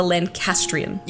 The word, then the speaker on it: Lancastrian: I kept saying Lancasterian. It is the Lancastrian.